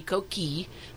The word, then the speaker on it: coquille: Properly in French, it should be coquille, but a lot of people don't know that two L's and an E aren't heard in French.